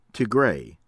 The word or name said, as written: Tigray